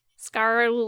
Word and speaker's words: scarpe: Scarpe. – Scarpe. – But you're supposed to roll your R. – Scarpe.